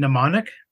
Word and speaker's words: Mnemonic: Mnemonic? – There we go.